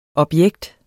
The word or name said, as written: objekt